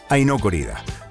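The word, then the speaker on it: Ai no Corrida: Bueno, y el título en japonés se pronuncia como el título de este tema, que es Aino Corrida.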